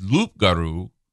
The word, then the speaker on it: loup-garou: But the French pronunciation referring to the swamp monster is loup-garou.